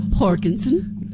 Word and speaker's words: Jorgensen: The John Jurgensen. – Jorgensen. – You could say that. – Do you say that? Do you pronounce the J? – Yes. – Jorgensen.